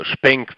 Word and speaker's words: Xpeng: Zum Beispiel eine, eine chinesische Firma namens Xpeng, also Spenk wird es ausgesprochen und Xpeng wird es ähm, geschrieben.